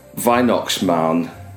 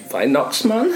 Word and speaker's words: Weihnachtsmann: And I'm going to badly pronounce this as Weihnachtsmann. Weihnachtsmann. I think my accent was really giving. – I'm guessing by the accent. Weihnachtsmann. – Yeah. – Schwei or Vi? – Vi.